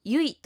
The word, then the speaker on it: YUI: アルファベットで YUI と書いてユイというそうですが、近藤さん、ユイについて詳しく教えていただけますか？